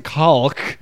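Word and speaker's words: caulk: Unless you pronounce it calk. – Calk. Macaulay Culkin. Calking, but everyone says cock.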